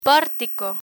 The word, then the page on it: portico: Spanish